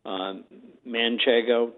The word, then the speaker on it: Manchego: And Pecorino Romano, Manchego, pronounce it right? – You do.